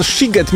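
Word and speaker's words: Sziget: Czy możemy ustalić, czy ten siget, mimo że jest przez SZ, to jednak jest sigetem czy sigetem? – Oczywiście, ponieważ w języku węgierskim szy czyta się jak sy, a sy czyta się jak szy.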